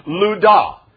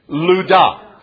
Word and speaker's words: Lydda: It's actually pronounced Lydda. Say it with me. Lydda.